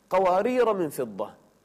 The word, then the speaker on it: قوارير: وسبحان الله، قواريرا الثانية وضعت عليها على الألف الصفر ليس المستطيل، المستدير مثل السكون، فهذا الصفر المستدير في قوارير الثانية يدل على أننا ما ننطق الألف لا وقفا ولا وصلا، قوارير من فضة، وإذا وقفت عليها قوارير فما تقول قوارير يعني في الثانية، قوارير من فضة.